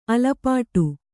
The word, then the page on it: ಅಲಪಟೆ: alapaṭe